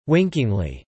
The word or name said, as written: winkingly